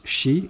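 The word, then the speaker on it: chi (四): Parce que quatre, ça se prononce chi, et chi, ça signifie la mort.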